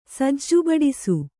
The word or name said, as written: ಸಜ್ಜುಬಡಿಸು